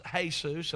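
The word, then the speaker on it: Jesús: And someone informed me that it's pronounced Jesus, and I get it.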